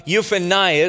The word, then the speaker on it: Jephunneh: Caleb, the son of Jephunneh, is how you'd pronounce that.